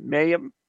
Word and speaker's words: Mayhan: And Mayhan? – Mayhan.